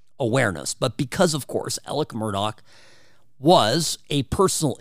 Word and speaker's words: Alex Murdaugh: Now, Alec Murdaugh, it's spelled Alex Murdaugh. But for whatever godforsaken reason down there in South Carolina, these people pronounce things differently. So I'm going with how he pronounces his name, Alec Murdaugh.